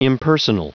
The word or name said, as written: impersonal